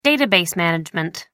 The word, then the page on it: database management: American English